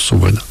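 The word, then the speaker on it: Saut-Brénaz: C'est-à-dire que justement, il y a une cascade, ça, c'est de là vient le nom de Solt-Brenna, comme on prononce Sobrena.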